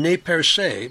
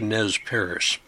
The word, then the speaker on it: Nez Perce: The French pronounced the name Nez Perce, but English speakers simplified the pronunciation to Nez Perce.